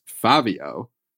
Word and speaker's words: Fabio: so it is pronounced Five-Yo. Though people who know him tend to drop the last letter or two. I'm going to go ahead and say from spending time with them, you know, New York accents are what they are. But I think at this point they've just landed on five.